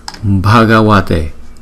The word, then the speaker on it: Bhagavate: B, Bhagavate, Bhagavate, Bhagavate.